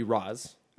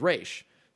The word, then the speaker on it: Ra's: But so it is Arabic for the demon's head, and the Arabic pronunciation would be Roz. – Okay. – But the creator went with the Hebrew pronunciation, which is Ra's.